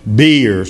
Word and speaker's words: bier: So they say it's pronounced beer.